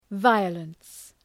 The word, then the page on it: violence: ‘vaıələns